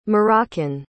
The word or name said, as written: moroccan